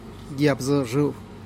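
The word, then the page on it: yab zu xru: Teotitlán del Valle Zapotec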